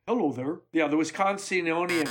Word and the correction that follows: Wisconsinan: How do you say Wisconsinonian? – Wisconsinan. – That's the last Wisconsinian. – Wisconsinan. Yeah.